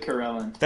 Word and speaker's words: Karellen: I said Karellen.